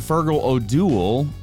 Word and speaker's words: Fergal O'Duill: We've been sitting here with Father Fergal O'Doul. Is that correct? Did I say that right? – You got it.